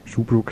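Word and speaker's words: Schaubroeck: Shoebrook. Shoebrook spricht sich das korrekterweise aus, habe ich jetzt nachträglich erst herausgefunden.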